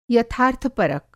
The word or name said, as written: यथार्थपरक